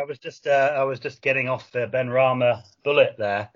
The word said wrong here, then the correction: Benrahma: So, no, it's Benrahma.